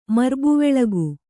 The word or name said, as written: ಮರ್ಬುವೆಳಗು